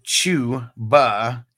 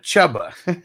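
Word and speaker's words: Chuba: And Peacocks, I never understood why it's pronounced Chew-ba instead of Chubba.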